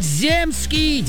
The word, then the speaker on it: земский: В данном слове во всех падежах и числах ударение ставится только на гласную «е». Поэтому правильно говорить «земский».